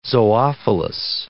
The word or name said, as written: zoophilous